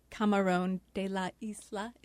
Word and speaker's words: Camarón de la Isla: Bo, we're going to play a clip that you recommended today, Flamenco Vivo by Camarón de la Isla.